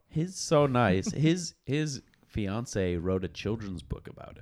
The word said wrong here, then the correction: fiancé: It's actually pronounced fiancé. – Sorry.